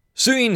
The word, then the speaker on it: Suyin: This is by Suyin Hayes. I guess that's how you say her name. S-U-Y-I-N.